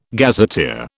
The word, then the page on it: gazetteer: British English